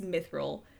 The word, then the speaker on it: mithril: David said it correctly. It's Mithril.